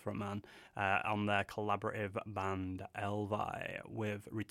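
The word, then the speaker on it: EL VY: That, you know, they've called themselves Elvy, I think it's pronounced. It's Elvy. According to a press release, it rhymes with hell pie.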